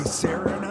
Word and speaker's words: Siri: It's not going to work, Dickie. You have to say it, Siri. You have to say it correctly.